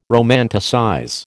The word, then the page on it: romanticize: American English